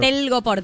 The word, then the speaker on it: Telgopor: Ay, sí, Tergopol no sé cómo se dice. ¿Cómo es Telgopor? – Telgopor, tela gomosa porosa.